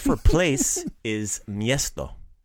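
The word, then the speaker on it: mjesto: So, the word for place is miesto. In the song, you'll hear it pronounced misto. Right? – Misto. – So, kind of maybe a little bit lazified it a little bit. Took out the mia and just put it m, like misto.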